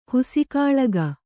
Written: ಹುಸಿಕಾಳಗ

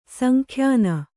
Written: ಸಂಖ್ಯಾನ

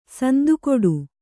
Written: ಸಂದುಕಟ್ಟು

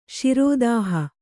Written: ಶಿರೋದಾಹ

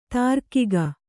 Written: ದೀರ್ಘಿಕೆ

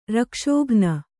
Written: ರಕ್ಷೋಘ್ನ

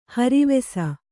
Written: ಹರಿವಾಸ

